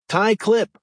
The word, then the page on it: tie clip: American English